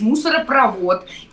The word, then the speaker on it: мусоропровод: Ургант говорит э, мусоропровод, я его поправила и сказала, что нужно говорить мусоропровод. И, э, соответственно, все слова, которые оканчиваются на провод, у них ударение идет на последний слог. Трубопровод, газопровод, нефтепровод и так далее.